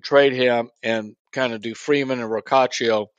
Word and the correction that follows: Rocchio: I always get this name wrong. – Rocchio.